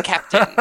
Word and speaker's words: Kapteyn: named Captain Star, which is spelled K-A-P-T-E-Y-N. Captain.